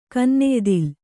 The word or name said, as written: ಕೆನ್ನೆಯ್ದಿಲೆ